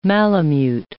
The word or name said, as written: malamute